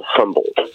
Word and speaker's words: HMBLDT: and they're called Humboldt. Not called Humboldt, like spelled like Humboldt County. It's HMBLDT.